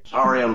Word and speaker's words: Saurian: Saurian brandy.